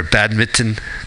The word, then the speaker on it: badminton: Oh, he said, is it too late to sign up for badminton? And I said, maybe in Connecticut. – Okay. – Because he pronounced an extra T.